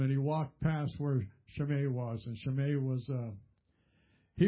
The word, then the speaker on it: Shimei: There's an interesting name there, Shimei. Some people will call it Shimei. It doesn't matter to me how you pronounce it. It's got an E-I at the end, so I that's an A to me.